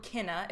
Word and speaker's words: Cinna: Oh no, just my favorite scene in the entire play is when so one of the conspirators is named Cinna or Cinna, if we're going by the Latin pronunciation.